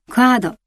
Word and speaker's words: カード: 口をあまり開けずにクアードと発音します。